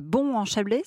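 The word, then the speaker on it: Bons-en-Chablais: Donc, à Bon-en-Chablais, c'est ça? – Exactement, à Bon-en-Chablais. – On prononce ou pas le S? – Alors, on ne prononce pas le S.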